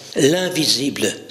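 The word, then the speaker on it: L'1visible: L'invisible, ça s'écrit L apostrophe, le chiffre 1 suivi de visible. Donc quand vous le prononcez, ça fait l'invisible, mais quand vous le décortiquez, l'un, celui qui est fondamentalement unique, s'est rendu visible, l'invisible.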